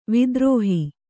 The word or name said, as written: ವಿದ್ರೋಹ